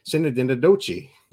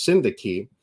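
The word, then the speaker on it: synecdoche: And the first video that we pop up is this hilarious video that does the like pronunciation frame and everything, but it's like Sinadinadochi. We all laughed knowing that was not it, but it was hilarious. It's pronounced syndaki, and it is a figure of speech where an object takes on the meaning of something else.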